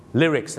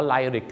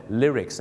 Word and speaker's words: lyrics: Lyrics นั่นเองครับหนังเรื่องนี้นะครับต้องขอขอบคุณมากนะครับเพราะก่อนหน้านี้พี่น้องชาวไทยออกเสียงว่าไลริกกันเต็มหมดเลยเนื้อร้องต้องเป็น lyrics